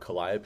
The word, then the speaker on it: Calliope: I mean, and now that we don't have Calliope. – Thank you for saying it correctly.